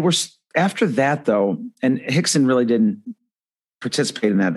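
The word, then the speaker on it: Rickson: But Royce Gracie and his half-brother, Hickson, and by the way, these are spelled with R's, but they're pronounced with H's.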